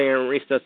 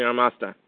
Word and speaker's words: Amarista: I think it depends on the accent. Some say Amarista, some say Armasta. – Uh, tomato, tomato. – Amar- Amar- Amarista. That, it's how it's spelled, actually.